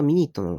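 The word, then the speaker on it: minute: ミニットっていうのは、分とか、そういう意味なんですけど、これ発音がミニットなの。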